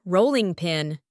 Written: rolling pin

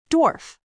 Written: dwarf

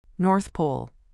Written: North Pole

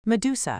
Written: medusa

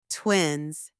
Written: twin(s)

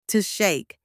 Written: to shake

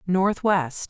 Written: Northwest

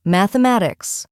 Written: mathematics

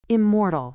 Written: immortal

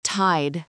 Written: tide

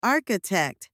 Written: architect

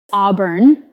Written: auburn

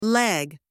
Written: leg

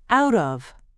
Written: out of

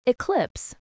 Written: eclipse

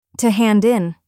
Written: to hand in

